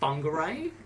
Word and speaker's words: Whangarei: It's actually spelt Whangarei, but it's actually pronounced Whangarei.